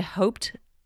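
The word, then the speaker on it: Haupt: It's called, I've actually never known how to pronounce this garden, the Enid Hoped Garden? – Hoped Garden, I'm not quite sure how to pronounce it either, but it's H-A-U-P-T, yes.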